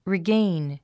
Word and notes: regain: riɡéin; リィゲイン

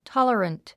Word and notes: tolerant: tάlərənt; タララント

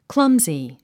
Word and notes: clumsy: klʌ́mzi; クラムズィ

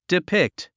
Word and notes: depict: dipíkt; ディピクト

